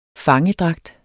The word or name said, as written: fangedragt